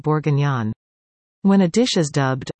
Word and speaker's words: bourguignon: Bourguignon, pronounced bourguignon, means of Bourgogne, Burgundy in English, an east-central region of France renowned for its wine.